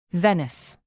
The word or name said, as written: venice